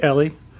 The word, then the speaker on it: Eli: Our butler, whose name was, it was spelled Eli, E-L-I, but he pronounced it Ellie.